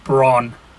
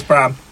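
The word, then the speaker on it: Brann: It's pronounced Bran. – Oh, is it? – Right. – Yeah. – Okay. – Everybody says Bram, but it's not.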